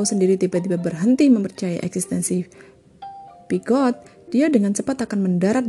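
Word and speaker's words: Peugeot: Uh, eh, Pugot, misalnya, bukanlah teman imajiner dari CEO Pugot, Pigot, Pigot ya. Tadi bacanya pijet, pigot, pugot. Sekarang oke, okay, pig, pigot aja pigot.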